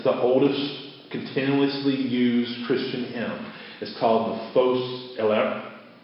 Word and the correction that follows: Phos Hilaron: Fos, I always mispronounce it. Phos Hilaron. Fos, yes, Phos Hilaron.